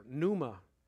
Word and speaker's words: pneuma: The Greek word there is pneuma. It's spelled P-N-E-U-M-A, pneuma.